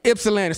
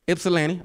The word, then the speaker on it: Ypsilanti: why is there a Y? I've been telling people Ypsilanti. All right, say that again for me. Ypsilanti. So the Y is silent. It don't make no sense.